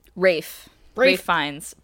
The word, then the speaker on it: Ralph Fiennes: Because that's got Ralph Fiennes. – Rafe Fiennes. But sorry, everybody. I pronounced both portions of your name wrong.